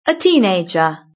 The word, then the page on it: a teenager: British English